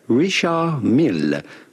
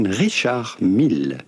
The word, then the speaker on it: Richard Mille: Do not pronounce the D to Richard. It's not like in English, you do not say Richard, but rather Richard. In English, Richard Mille. Do pronounce the double L. Richard Mille, with the typical French or Swiss French pronunciation, Richard Mille.